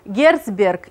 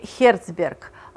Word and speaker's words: Герцберг: За ним идет Альдерфер, Макклелланд, и вот его по-разному пишут и произносят, Герцберг или Херцберг, но чаще Герцберг, хотя, если увидите иначе написано, в принципе, имейте в виду, что это один и тот же человек.